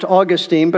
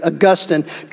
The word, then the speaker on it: Augustine: Long before Calvin ever taught or wrote about these doctrines, a church father from the fourth century by the name of Augustine, sometimes pronounced Augustine, but probably more correctly Augustine, championed many of the doctrines that have come to be known as Calvinism.